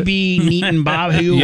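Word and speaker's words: Bibi Netanyahu: How you say it? – No, you got it. You got BB Netanyahu. – Yep. Nailed it.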